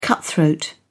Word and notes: cutthroat: British English

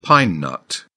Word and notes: pine nut: British English

